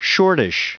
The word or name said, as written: shortish